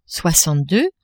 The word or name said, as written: soixante-deux